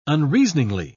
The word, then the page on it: unreasoningly: British English